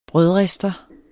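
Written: brødrister

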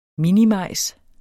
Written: minimajs

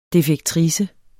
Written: defektrice

